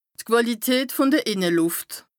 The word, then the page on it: d' Quàlität vùn de Ìnnelùft: Bas Rhin